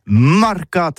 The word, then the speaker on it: marcat: Eh bien, d'ici à toutis, le mercat, de l'Uni, ou le mercat des rebelles, mais en gascogne toulousano, c'est prononcé sur les marcat, avec un A, marcat.